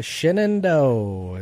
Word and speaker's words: Shenandoah: Go out with Shenandoah is how I'm taught to pronounce that now.